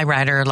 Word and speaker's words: Ryder: I am Turi Ryder, in for Joan. It's spelled with U, T-U-R-I, Ryder, like the rental truck.